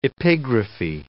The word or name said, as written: epigraphy